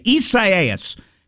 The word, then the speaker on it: Isaias: All right, and now the latest on Hurricane Isaias. That's how you say it. I guess I was saying it wrong yesterday. Isaias.